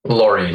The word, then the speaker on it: Lawrie: Is it Lawrie or Lowry? – Lawrie, like a truck in England.